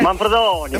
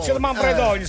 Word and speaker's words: Manfredonia: Dillo bene la pronuncia, dai. Com'è che è? – Manfredonia. – Manfredonia, sì.